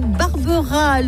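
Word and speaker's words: Barberaz: Sortie 18, Barbera, le Z ne se prononce pas, Aymeric, on vous l'a dit.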